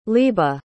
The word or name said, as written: leeba